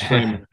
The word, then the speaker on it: Frymire: Well, my name is Derek Frymire. Everyone gets the last name wrong. I always say Frymire. It's Frymire.